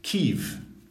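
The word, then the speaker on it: Kyiv: Yeah, they've now used their correct pronunciation. So, Kiev.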